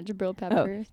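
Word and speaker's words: Jabrill Peppers: Jabril Peppers, that's how you say it? – Yeah, Jabril Peppers.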